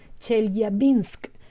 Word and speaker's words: Chelyabinsk: No es de extrañar entonces que se especule sobre Chelyabinsk. Ah, perdón la pronunciación, la pronunciación, Chelyabinsk, perdón, como escenario de una invasión alienígena.